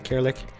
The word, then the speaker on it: Kirilik: The word is made up. It's supposed to be Cyrillic, like the alphabet we use in Bulgaria, but it seems that some guys from Macedonia are using the name for a couple of years now, so I had to change it. I just swapped the letter, so it's just K. The word doesn't exist, so I guess Kirilik is the right way to pronounce.